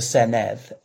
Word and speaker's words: Senedd: because it's got it's 96 members of the Senedd, MS. So Senedd being the Welsh word for parliament, it looks a bit like Senate. The double D is pronounced like a TH, like th, not that I am a Cymrophone.